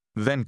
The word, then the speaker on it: δεν: Did you hear the two different TH sounds? Listen and repeat to practice your pronunciation. – Θέλω. Δεν. Δεν θέλω. – It's like the difference between the words then and think.